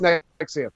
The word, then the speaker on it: NXIVM: So Nexium, and it's so what's Nexium? Yeah. – It's spelled NXIVM, but it's pronounced Nexium. Don't ask me why.